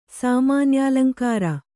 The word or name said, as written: ಸಾಮಾನ್ಯಾಲಂಕಾರ